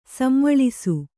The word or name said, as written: ಸಂವಳಿಸು